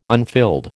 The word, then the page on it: unfilled: American English